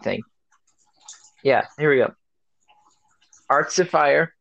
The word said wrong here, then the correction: Artificer: I don't know if I'm saying that right. – Artificer. – Yeah, there we go.